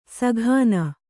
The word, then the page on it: ಸಘಾನ: saghāna